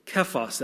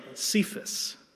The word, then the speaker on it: Kephas: When Paul finally did go to meet up with the other apostles, 3 years after his conversion, he spent time only with Kephas, that's Peter, and yes, that's pronounced Kephas, not Cephas.